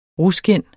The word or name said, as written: ruskind